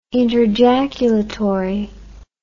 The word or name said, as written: interjaculatory